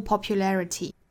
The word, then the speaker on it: popularity: popularity or popularity 的重读音节应该是在 le 这个音节上